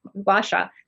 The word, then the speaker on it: Gua Sha: moxibustion and Gaia? – What's the last one? – GUA? – Oh, Gua Sha. Okay. I think that must be missing part. Gua Sha.